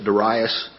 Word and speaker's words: Darius: some people pronounce that Darius, some pronounce it Darius, and I find myself pronouncing it both ways as I read through here.